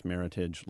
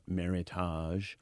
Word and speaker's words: Meritage: But what people are doing is instead of using some sort of widely accepted name like Meritage, and it is pronounced Meritage like heritage, not Meritage. Even though it looks like we're supposed to be all Frenchy about it.